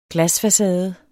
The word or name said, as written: glasfacade